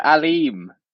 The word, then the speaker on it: Aleem: Oh, Aleem. – Aleem Dar. Because his name is spelled A-L-M.